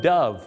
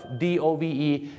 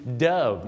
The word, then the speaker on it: dove: dove d o v e dove นะครับไม่ใช่ dove นะฮะต้องออกเสียงว่า dove